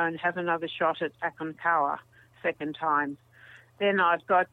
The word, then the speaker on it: Aconcagua: But I miss the Americas, so I'm booked in for South America, Aconcagua, which is how the Spanish pronounce it in the in South America, in Chile or Argentina.